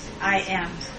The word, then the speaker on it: Mahanaim: So he was at Manna, Mannaheim. Spell it for me. – Mahanaim.